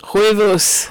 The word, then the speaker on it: Huevos: And then I'm going to say this wrong, but Huevos Ranchos? Is that how you say it? – Huevos. – Huevos.